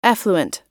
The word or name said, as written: effluent